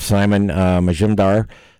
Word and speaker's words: Simon Majumdar: I'm JT, along with Reynolds, and we've got Chef Simon Majumdar there.